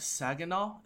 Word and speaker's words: Saginaw: Saginaw.